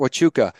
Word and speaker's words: Huachuca: Fort Huachuca.